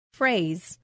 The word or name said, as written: phrase